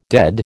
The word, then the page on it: dead: American English